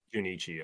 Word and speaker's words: Junichi: Is that how you pronounce that? – Junichi. – Yeah. Is it Junichi? Okay.